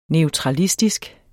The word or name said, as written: neutralistisk